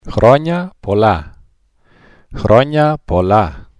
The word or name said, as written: chroniapolla